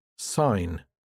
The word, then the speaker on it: sign: The silent G. In general, the G isn't pronounced when it comes after an N. Foreign, cognac, champagne, sign, design, align.